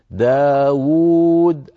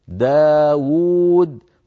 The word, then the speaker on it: داود: داود أيضا لفظها بواوين وكتبت بواو واحدة، فزاد علماؤنا بخط صغير واو ثانية ليبينوا للقارئ بأن هذه الكلمة تقرأ بواوين، داود الواو الأولى مضمومة والواو الثانية ساكنة مدية على وزن فاعول داود، فلا بد من نطق كلا الواوين ولو كانت في الخط القديم بواو واحدة كما أسلفت.